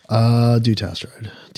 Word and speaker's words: Dutasteride: Dutasteride. – Dutasteride.